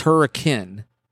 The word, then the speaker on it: Hurricane: It's not hurricane. It's hurricane.